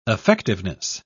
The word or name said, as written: effectiveness